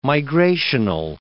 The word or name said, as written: migrational